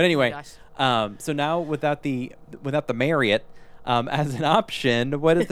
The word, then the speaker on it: Marriott: So that Marriott, by the way, I learned from like the boss of the Marriott resort chain that it's actually pronounced Marriott, like chariot, and we've all been pronouncing it wrong.